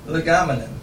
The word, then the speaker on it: legomenon: Hapax legomen. How do I pronounce that, Baron? Legomenon. I put the emphasis on the wrong syllable every now and then.